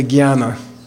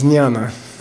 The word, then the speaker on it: jnana: Some people in India say jnana, but it's jnana. – Okay, it's jnana. Okay, it's jnana. It's like a Z-N-Y, or something.